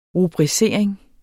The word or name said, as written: rubricering